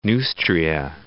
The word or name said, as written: Neustria